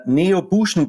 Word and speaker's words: Neobution: Wir sind unglaublich gespannt, dass wir heute den Timo und den Andi von, und jetzt möchte ich es richtig aussprechen, von der Neo Buschen GmbH bei uns haben.